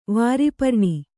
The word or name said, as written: ವಾರಿಪರ್ಣಿ